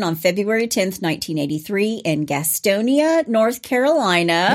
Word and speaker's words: Gastonia: And when living in North Carolina, that's how you say it. You don't say Gastonia. – It's Gastonia.